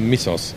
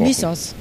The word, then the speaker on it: Mythos: Und Mythos wird aber Missos ausgesprochen. – Missos.